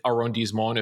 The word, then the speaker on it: arrondissement: The Left Bank area, though, this is in the 5th arrondissement, if you know Paris. Beautiful area of the city. – That is some A-plus pronunciation.